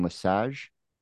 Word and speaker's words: LeSage: So today on the show, I've got Jason LeSage.